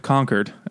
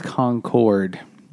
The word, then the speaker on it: Concord: You pronounced it correctly. I'm so proud of you. – How else would you pronounce it? – Concord. – The wrong way is Concord. It's not the right way to say it.